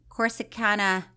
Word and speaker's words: Corsicana: Corsicana. – Yeah. – Did I say it right? Texas. – That time Fruitcake, you did.